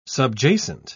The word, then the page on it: subjacent: British English